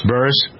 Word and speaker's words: Buress: Buress, right? – Buress.